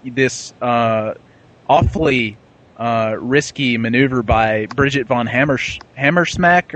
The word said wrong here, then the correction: Hammersmark: or I can't remember how to pronounce her name. – Hammersmark. – Right, Hammersmark, that's right.